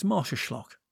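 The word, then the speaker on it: Marsaxlokk: Another town with a vibe is Marsaschlag. Believe me, that's not how you'd expect it to be pronounced if you saw it written down.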